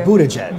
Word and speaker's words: Buttigieg: Como que é essa pronúncia? – Buttigieg. Buttigieg. É. Buttigieg. Buttigieg. Falei certo? Pete Buttigieg e M.